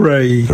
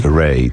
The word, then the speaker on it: Raye: Kim wymawi się Ray. – Ray. Tak jak ta Ray z Gwiezdnych Wojen. – Tak, dokładnie. Chociaż pisze się R-A-Y-E.